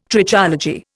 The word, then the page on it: Trichology: Indian